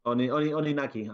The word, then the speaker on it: Oninaki: I am hoping that forgot the name, Oninaku. It's good. – Oninaki. I'm sorry. – Oninaki. Even you said it wrong, even though you're the Nintendo expert. Oninaki. Oninaki is good.